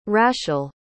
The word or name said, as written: raschel